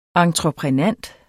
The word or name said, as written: entreprenant